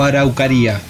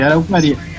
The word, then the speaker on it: Araukkaria: ¿Araucaria o Araucaría? ¿Cómo es la pronunciación? – Araucaria. – Araucaria, Araucaria como, sí, como sí.